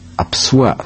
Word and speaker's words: Апсуа: Они называют себя Апсуа с ударением в конце, хотя нынешняя литературная норма рекомендует ударение делать на первом слоге.